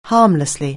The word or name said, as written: harmlessly